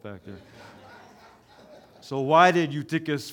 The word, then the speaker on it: Eutychus: How do you say it, Pastor Rolf? Say it again. – Eutychus. – He says Eutychus. There we go.